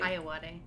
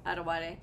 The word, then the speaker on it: Ayoade: Am I saying his name correctly? – Ayoade. – Richard Ayoade. – Okay, Ayoade. Was that while we were watching this... – You had it right, and then you still tried to say it too fast.